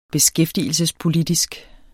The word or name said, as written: beskæftigelsespolitisk